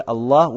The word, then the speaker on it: Аллах: Если валь иля, иля мягко произносится, то в слове Аллах уже твердо произносится.